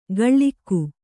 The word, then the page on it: ಗಳ್ಳಿಕ್ಕು: gaḷḷikku